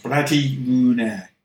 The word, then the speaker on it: Blade Runner: And it is called Bloody Rune, I believe it's pronounced. Bloody Rune. – Yes, that is correct.